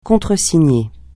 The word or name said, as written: contresigner